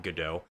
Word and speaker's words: Godot: It's not Godot, but it's also not quite Godot. Her confusing advice is that the T is pronounced as a lighter T, a softer T.